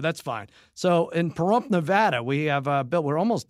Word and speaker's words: Nevada: And I've been saying that wrong. Harry, you should correct me. I think Jimmy gets on my case, too. My cousin Jimmy is from there. He says I say Nevada, and it's Nevada, and then I got a couple of letters saying it's Nevada. I'm saying it wrong. How do you say it, Harry? – It's Nevada. – It's Nevada. Okay.